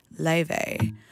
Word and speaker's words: Laufey: We also listened to Silver Lining by Laffy, Lave, Lave. Wait a second. How do I pronounce this? L-A-U-F-E-Y, Lave.